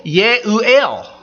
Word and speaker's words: Jeruel: When I was in Israel a couple years back, our tour guide, I pointed this out in the Bible to him, and I said, how do you say that in Hebrew? And he goes, oh, Ye'u'el. I go, shoot, now it's more confusing than ever. And In Hebrew does not pronounce a J sound.